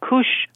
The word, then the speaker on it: cush: And you mentioned cushing. Cushing. Is that how you pronounce it? – Yeah, cush. Cush.